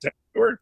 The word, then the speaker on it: Tag Heuer: So am I saying it right? Tag Heuer? – Yeah, Tag Heuer, yeah. – Tag Heuer. No, it's not right. I didn't it's close. – Right. – Come on, it's close.